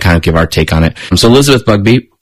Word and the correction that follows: Busby: No, Bugby. Elizabeth Busby. – Busby. – Is it Busby?